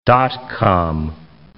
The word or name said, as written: dot-com